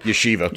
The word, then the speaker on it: yeshiva: The second article is a story from a Jewish kid who went to a yeshiva. I believe that's how you pronounce that. – Yeshiva. – Yeshiva. So I think ask the Jew.